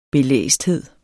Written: belæsthed

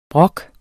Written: brok

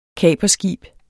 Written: kaperskib

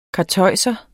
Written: karteuser

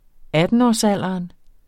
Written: attenårsalderen

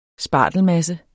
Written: SPARTELMASSE

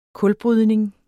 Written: kulbrydning

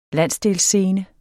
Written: landsdelsscene

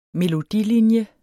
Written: melodilinje

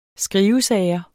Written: skrivesager

